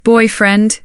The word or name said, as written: boyfriend